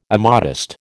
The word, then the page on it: immodest: American English